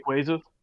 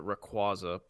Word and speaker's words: Rayquaza: Rayquaza. – Rayquaza. – That's apparently the official pronunciation. – Weird. I would have pronounced it Rayquaza, but whatevs.